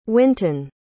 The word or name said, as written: winton